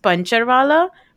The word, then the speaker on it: Puncturwala: It's pronounced Pancharwala, but it actually is Puncturwala, which is a tire repair person.